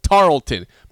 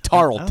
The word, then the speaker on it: Tarleton: Yes, I'm saying Tarleton. Boston College lost by 16 points to a school spelled T-A-R-L-E-T-O-N. Tarleton. – I think there's only one way to pronounce that, right? – Right. It's Tarleton.